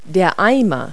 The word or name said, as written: pail